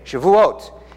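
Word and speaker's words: Shavuot (שבועות): So if you want to say week in Hebrew, Steve, how do you say it? Shavuot. If you want to say sevens, Steve, how do you say it?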